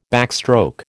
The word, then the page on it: backstroke: American English